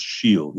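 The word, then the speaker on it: O'Siadhail: I mean the classics like George Herbert or Gerard Manley Hopkins, and particularly the great contemporary Irish poet Michal O'Shiel, O'Shiel, pronounced Shiel.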